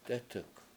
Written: dEtuk7

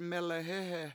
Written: melehEhe

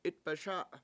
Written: 7it$baSA7

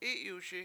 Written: I7yuSi7

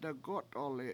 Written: degO7t1li7

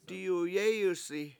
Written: diyuyEEyusi

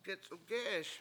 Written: gec7ugE7eS

